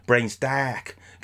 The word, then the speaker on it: Brains Dark: So I will put one on tap, and it is Brains Dark, or to give the correct pronunciation, Brains Dark, because it's Cardiff.